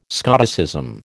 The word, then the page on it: Scotticism: American English